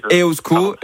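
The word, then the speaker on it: Eusko: Non, mais vous faites bien déjà, Iban, vous dites EOSCO, donc on va déjà le prononcer correctement, ça c'est déjà moins de...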